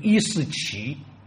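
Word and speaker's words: 伊嗣俟: 萨珊末代国王伊斯四三世，请大家注意这个名字，很多朋友读成伊斯四，但是我个人建议应该读成伊斯奇。如果参照古代波斯语的名称，他以读为伊斯奇比较妥当。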